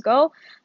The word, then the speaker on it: Goh: His name is Go, uh, G O H. That's how it's spelled.